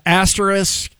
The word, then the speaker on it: asterisk: How about asterisk? Is it the most commonly mispronounced word as an asterisk? – Asterisk, yeah.